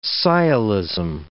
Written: sciolism

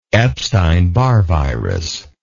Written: epstein barr virus

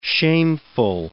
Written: shameful

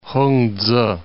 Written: Hungtse

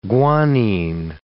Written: guanine

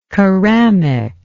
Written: keramic

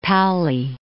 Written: Pauli

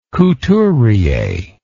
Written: couturier

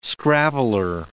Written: scrabbler

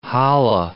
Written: hallah

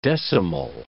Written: decimal

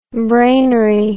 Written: brainery

